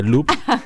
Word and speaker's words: Louvre: No la Ubre, sino de Louvre.